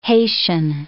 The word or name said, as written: Haitian